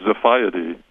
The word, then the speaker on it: Xiphiidae: It's a Greek swordfish, and it's spelled X-I-P-H-I-I-D-A-E, and it's pronounced Ziphiidae.